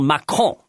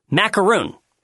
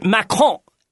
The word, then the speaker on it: Macron: So I want to tell you, it is not Macaroon. It can be confusing, but I will tell you how to remember that it's not macaroon. See, macaroon has a filling to it. And candidate Macron is so empty inside that it's easy to make the mistake, but there's absolutely nothing there.